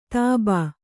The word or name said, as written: ತಾಬಾ